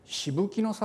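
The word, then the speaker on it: 紫服: もう一つ見ていただくと、その萩から今度車でまた 3,40 分ぐらい少し山の中に入っていったところにしぶきの里というのがあります。あれでしぶきって読むんです。紫の服と書いてしぶきと読むんですけれども、 Google の地図で見てもらってもほとんど出てこないぐらい小さな集落なんですが、ここにキリシタンの碑があるんですね。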